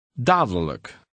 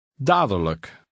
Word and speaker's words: dadelijk: Dadelijk. – Notice that the middle D in this word is almost silent. Also, the L-I-J-K ending is always unstressed and is pronounced.